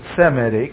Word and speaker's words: Semitic: Semitic, or Shemitic, we pronounce it Semitic, Semitic nation, spirituality.